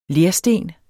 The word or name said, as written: lersten